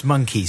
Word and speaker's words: Mynci: It's pronounced Monkey, spelled Monkey. M-Y-N-C-I.